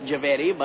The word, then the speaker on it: Javeri: Mr. Jarvie, right? – Javeri, but you can call me B-E-N.